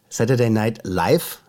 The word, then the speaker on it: Saturday Night Live: Und die wird gespielt von Saturday, Saturday Night Live. Saturday Night Live, so ist die Betonung.